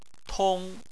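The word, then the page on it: tong: tone 1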